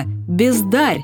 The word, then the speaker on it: бездарь: Футурист Игорь Северянин для «бесталанных людей» придумал новое слово – «бездарь», которое мы сейчас произносим с ударением на «е».